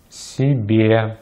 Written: себе

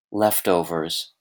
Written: leftovers

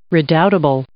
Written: redoubtable

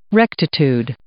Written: rectitude

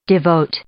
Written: devoted

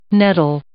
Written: nettle

